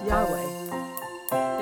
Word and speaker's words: Yahweh: It is YHWH in Hebrew, pronounced sort of as Yahweh.